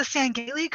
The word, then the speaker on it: Sangheili: I was like, well, am I pronouncing the Sangheili correct? He says, yes, you are.